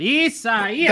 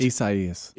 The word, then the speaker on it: Isaias: Isaias. That's how you say it. – Isaias.